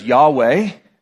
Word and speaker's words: Yahweh: And they translated, because they're Hebrew letters translated into English, it's either YHWH or JHVH. You will have heard them pronounced either as Yahweh or Jehovah.